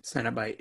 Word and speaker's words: Cenobite: Cenobite, yeah.